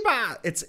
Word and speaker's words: Ribas: Ribas. It's Amanda Ribas. The R is silent.